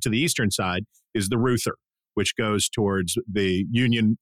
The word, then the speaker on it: Reuther: It's spelled like R-E-U-T-H-E-R, and it's pronounced Reuther.